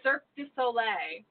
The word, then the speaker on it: Cirque du Soleil: Did I say that wrong? Because you're giving me that look. Did I? – The Circus de Soleil. – What is it? – Cirque du Soleil.